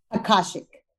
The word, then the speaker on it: Akashic: You're also I'm going to say this right. Akashic records reader? – Akashic. – I was so close.